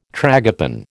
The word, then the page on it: tragopan: American English